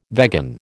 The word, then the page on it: vegan: American English